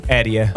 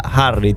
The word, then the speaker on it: Erie: Meglio. È Erie. – Erie. Ma io pensavo Harry, tipo il lago Styles, Harry.